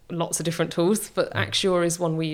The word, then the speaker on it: Axure: Axure, AXURE.